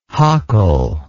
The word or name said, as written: hockle